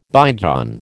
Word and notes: bygone: American English

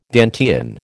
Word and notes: Dantean: American English

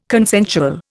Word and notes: consensual: Indian

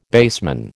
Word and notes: baseman: American English

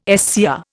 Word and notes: SCR: Indian

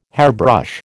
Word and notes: hairbrush: American English

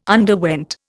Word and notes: underwent: Indian

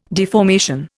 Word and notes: deformation: Indian

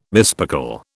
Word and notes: mispickel: British English

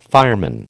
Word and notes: fireman: American English

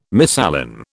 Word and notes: misalign: British English

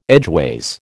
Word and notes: edgeways: American English

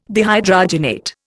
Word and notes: dehydrogenate: Indian

